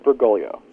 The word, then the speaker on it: Bergoglio: I believe it's pronounced Bergoglio. – Bergoglio. Okay. – I think the second G is silent, or at least sort of not very loud.